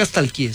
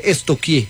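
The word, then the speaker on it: stalkear: Porque no se dice nunca estalquié, se dice estoquié.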